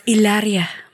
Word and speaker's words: Hilaria: It is pronounced Hilaria Baldwin. – Oh yeah. – Hilaria. It is Hilaria, and she will correct.